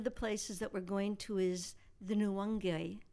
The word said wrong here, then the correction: Nyungwe: And I say that incorrectly each time. It's pronounced Nyungwe. – Yeah. – Nyungwe.